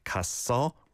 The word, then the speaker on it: 갔어: But you need to pronounce it 갔어 rather than 가서. Okay? Just nitpicking to give extra tips. – 폭풍 같은 하루가 지나갔어. – 지나갔어. 갔어. The double shot sound is on that first syllable there. 갔어. And then blends over to the second one.